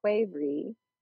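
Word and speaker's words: qvevri: The Russian winemaking pots called kuvri, and it's spelled really fun. It's Q-V-E-V-R-I.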